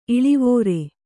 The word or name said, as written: ಇಳುವರಿ